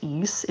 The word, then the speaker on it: Ys: We've we've hit one of these before where it's stylized as YS, and I think that's pronounced East.